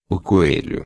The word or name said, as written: coelho